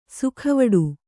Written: ಸಾಗುವಾಡ

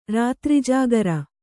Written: ರಾತ್ರಿಜಾಗರ

